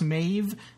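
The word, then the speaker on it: Maebh: I think it's pronounced Maeve, like as if it was a V, as in Vincent, but it's a B, as in boy. It's M A E B H, which threw me off, and that's why I was calling her Maeve.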